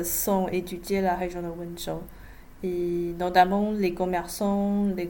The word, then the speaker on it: Wenzhou: Alors vous êtes surtout concentré sur l'étude de personnes originaires de Wenzhou, on prononce comme ça. – Wenzhou, oui.